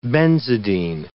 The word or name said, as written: benzidine